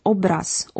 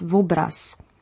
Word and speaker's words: obraz: There is, for example, the prothetic V, such as in the word obraz, or painting, which is commonly pronounced as vobraz.